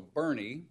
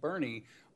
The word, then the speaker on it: Boerne: So Bernie, which is spelled in a very odd way, B-O-E-R-N-E, but Bernie on the west-hand side,